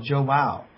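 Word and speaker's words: João: It's Joao. It's like wow, but with a J. – Oh, Joao.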